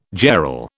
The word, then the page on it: jarl: British English